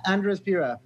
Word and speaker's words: Andres Pira: How do we pronounce it exactly? – Andres Pira. – Andres Pira. Okay, very cool, man.